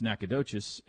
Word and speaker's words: Nacogdoches: And the one in Texas is pronounced Nacogdoches, and the one in Louisiana is pronounced Natchitoches. Pretty sure that's right.